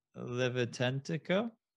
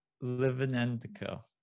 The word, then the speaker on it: Lytvynenko: So Roman Lytvynenko. – Nope, not it. – Well, how do I pronounce it? – Lytvynenko. – Lytvynenko.